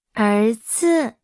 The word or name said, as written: 儿子